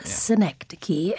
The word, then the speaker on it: synecdoche: Synecdoche. Synecdoche. I probably pronounced it synecdoche in my own head when I first saw it.